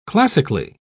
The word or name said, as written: classically